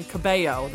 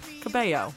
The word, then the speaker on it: Cabello: The L is in the first name, Camila, and then Cabello. The L is silent in the second name. Camila Cabello. – Yeah. – It's right there in phonetics on her Twitter account.